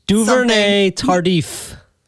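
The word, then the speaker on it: Duvernay-Tardif: Lauren Duvernay Tardif.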